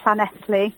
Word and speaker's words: Llanelli: I live in Redditch in Worcestershire, but I've come for a few days away in Llanelli.